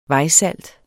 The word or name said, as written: VEJSALT